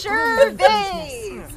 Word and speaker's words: Gervais: It's Gervais! Like the vase you put flowers in. – I even practiced this time. I still got it rhymes with Dane.